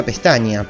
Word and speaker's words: Pestagna: Bueno, se pronunciaría Pestaña, pero se escribe así, Pestagna, así con G.